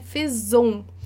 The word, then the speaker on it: faisons: Mas eu aproveito aí para observar com você que é faisons, tá? Por quê? Porque um S no francês tem som de Z.